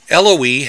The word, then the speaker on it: Eloi: Eloi has three syllables, rhyming with the names Eloise and Heloise.